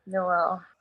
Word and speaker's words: Noelle Wells: And is her name Noelle Wells? Is that how you say it? – That's it. That's, yeah, Noelle.